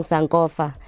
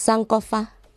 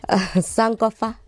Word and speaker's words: Sankofa: In fact, I was told by a friend of mine, because I kept saying Sankofa and pronouncing it like a Xhosa person. – Right. – And she called me and she said, it's Sankofa. – Yeah. – Sankofa.